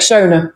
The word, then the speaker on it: Shona: Shona, yes.